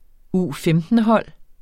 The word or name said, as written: U15-hold